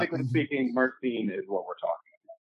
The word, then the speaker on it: myrcene: Mycrine. Okay. I was saying it wrong. – Mycrine. – Okay. Mycrine, myrcene. I mean, the scientific term is myrcene.